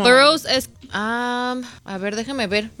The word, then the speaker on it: Burroughs: ¿Cómo se dice en inglés? ¿Es el The Burrows? – A ver, déjeme ver, ¿cómo se escribe? – B-U-R-R-O-U-G-H-S. – The Burrows. – Burrows.